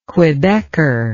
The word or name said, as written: quebecer